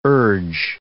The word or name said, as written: urge